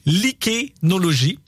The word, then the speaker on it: lichénologie: Elle poursuit ici un travail qu'elle a commencé en 2019 avec des chercheurs spécialisés en lichénéologie, parce que oui, c'est un mot que j'ai mal prononcé, lichénologie, voilà.